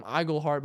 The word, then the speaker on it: Iglehart: And so I'm telling him Iglehart, but he's reading Inglehart. They added the N in there. No, he turned it around. They spelt it Inglehart.